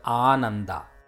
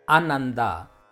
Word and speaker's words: Ananda: You might have noticed from my pronunciation of the word, the first syllable is A. If we don't sustain that akshara or syllable for the required length and pronounce the word as Ananda, then the word changes.